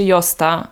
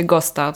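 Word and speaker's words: Gösta: E, nazywa się Josta, a pisze się Gosta.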